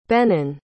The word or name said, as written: benen